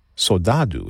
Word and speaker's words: Saudade: Saudade is how I believe they pronounce it in Brazil.